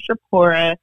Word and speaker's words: Shirpora: My name is Shapora. Y'all can find me on IG at Jess Shapora. My name is spelled S-H-I-R-P-O-R-A.